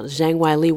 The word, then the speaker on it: Zhang Weili: Well, I will admit that I thought Zhang Weili, say it correctly now, Zhang Weili was going to win, but I did not anticipate a KO in 42 seconds.